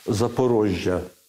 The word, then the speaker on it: Запорожжя: Е, Е, тому що за порогами треба мати на увазі і вимовляти правильно Запорожжя.